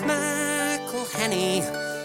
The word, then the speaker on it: McElhenney: It's McElhenney. Ways to massacre and mispronounce it, there are many. – McElhenney. – It's McElhenney. – McElhenney. – McElhenney. If there was doubt, now there won't be any.